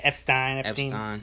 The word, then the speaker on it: Epstein: Yeah, Epstein.